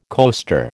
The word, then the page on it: coaster: American English